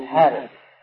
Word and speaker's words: الحارث: لا ابن الحارث، لكن هم مثل الحارث ما يحطون فيها ألف، هذا ينطق بالألف ولا تكتب، مثل هذا أي هذا فيها ألف بين الها والزال، نطقا فيها ألف لكن كتابة لا.